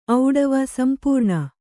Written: ಔಡವ ಸಂಪೂರ್ಣ

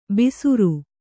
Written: ಬಿಸುರು

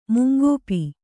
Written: ಮುಂಗಪ್ಪೆ

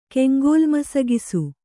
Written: ಕೆಂಗೋಲ್ಮಸಗಿಸು

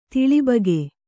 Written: ತಿಳಿಬಗೆ

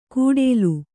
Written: ಕೂಡೇಲು

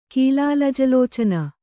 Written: ಕೀಲಾಲಜಲೋಚನ

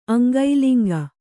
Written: ಅಂಗೈಲಿಂಗ

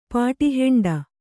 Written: ಪಾಟಿಹೆಂಡ

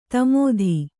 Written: ದಮಥ

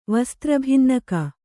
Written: ವಸ್ತ್ರಭಿನ್ನಕ

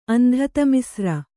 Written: ಅಂಧತಮಿಸ್ರ

